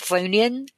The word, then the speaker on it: chthonian: So, with this, this is where we get more information on the Chthonian demons. Is how am I doing on that pronunciation? Chthonian? – I think technically it's Chthonian. – Okay. – But I always just say Chthonian.